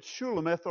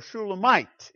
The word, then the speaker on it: Shulamite: The consonants for Solomon are the same here in her name, but with differing vowels, such that we pronounce it Shulamith or Shulamite instead of Solomon.